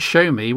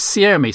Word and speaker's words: Xiaomi: and he pronounced it Show Me, when we clearly know that from our own resident Chinese speaker on PSC that it's Xiaomi. So clearly there are regional differences, and who's to say there aren't Chinese people who also pronounce it Oppo?